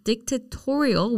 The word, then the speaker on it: dictatorial: So 방금 말씀해 주신 dictatorial, which has an emphasis on torial, right? – Mm-hmm.